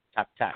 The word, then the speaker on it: tack: Tack, tack.